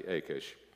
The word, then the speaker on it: Achish: The servants of Achish said, by the way, did you notice the editor of Psalm 34 got the name wrong? It's actually Achish.